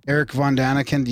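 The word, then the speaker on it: Erich von Däniken: Eric von Daniken, you said that right.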